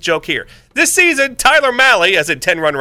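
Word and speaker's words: Mahle: Tyler Malley, and I always remember how to pronounce his name because it rhymes with 10-run rally, because that's what he's usually going to give up on the mound.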